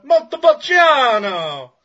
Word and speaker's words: Montepulciano: You know, you should say it like, you know, Montepulciano!